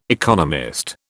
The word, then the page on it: economist: British English